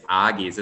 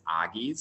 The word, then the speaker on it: Agi's: So actually none of those. It's it's Aggie's. It's okay. No, I knew it would come with the territory that people would probably not know how to pronounce it, but that is totally fine. It's Aggie's.